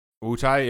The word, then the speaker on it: Wutai: Wutai, yeah.